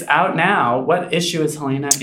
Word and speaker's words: Heléne: I love that you emphasize the pronunciation of Helena. – Helena.